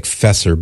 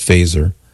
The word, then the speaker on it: Feser: So Fesser, his name is spelled F-E-S-E-R. It looks like Fesser, but it's pronounced Fesser, as in set your phasers to stun.